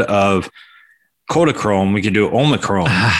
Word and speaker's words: Omicron: Omicron? Is that how you say it? – That is how I've heard it most cited. Yes, Omicron.